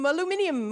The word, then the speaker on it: aluminium: Something that in England is pronounced a little differently. – Hi-ho, aluminium, away!